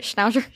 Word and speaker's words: Schnauzer: Did I say that right? Schnauzer? – Schnauzer.